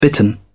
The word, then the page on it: bitten: British English